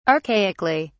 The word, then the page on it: archaically: British English